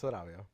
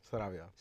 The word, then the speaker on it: Sarabia: It's Sarabia. – Sarabia? – Sarabia. – Sarabia. – Roll the R.